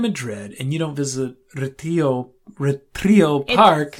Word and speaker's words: Retiro: How do you say it? – Retiro. – Retiro, the Retiro Park. – Like retired, that's what it is.